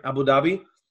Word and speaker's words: Abu Dhabi: Abu Dhabi.